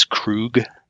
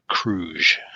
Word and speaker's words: Kruge: And I have to keep reminding myself that I'm glad they pronounced his name. It's Krug. – Yep. – Not Kruge. So I think I always want to say Kruge. – Yeah. The Klingon. It's a hard G.